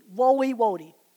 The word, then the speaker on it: Woiwode: And if you want to look that survey up online, her name is spelled Woliwode. It's a funny name. I'll spell it for you. W-O-I-W-O-D-E. Woliwode. W-O-I-W-O-D-E. Ruth Woliwode.